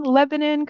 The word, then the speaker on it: Lebanon: Mark Sellew is the president and owner of Pride's Corner Farms in Lebanon, Connecticut. Am I saying Lebanon correctly, or is it Lebanon? – Nope, you said it just like you say in Pennsylvania.